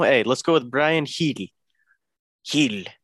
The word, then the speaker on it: Gil: I think it's Heel, bro. – Heel? – I'm looking it up right now. The pronunciation of Bryan, well, it would be Heel.